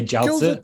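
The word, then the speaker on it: jiaozi (饺子): And say jiaozi, because those are my favorites. Except, oh my gosh, how do you pronounce it? I'm terrible. – Oh, no. I went with the Chinese pronunciation.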